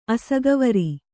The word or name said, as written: ಅಸಗವರಿ